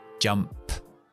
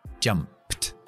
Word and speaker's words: jumped: Entonces, Pongo la ED pero pronuncio P más T. Jumped. Jumped. Jumped.